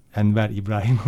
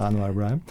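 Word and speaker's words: Anouar Brahem: Tunuslu ut sanatçısı Anwar Ibrahim. Enver İbrahim olarak da okunuyor aslında. Evet. Anwar Ibrahim.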